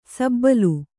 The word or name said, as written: ಸಬ್ಬಲು